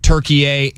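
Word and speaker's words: Türkiye: According to Turkey A and a newspaper over there, it's been spelt and pronounced Turkey A in the Turkish Republic since around 1923.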